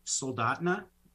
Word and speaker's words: Soldotna: We pronounce it Soldotna, the name of our community.